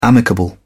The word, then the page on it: amicable: British English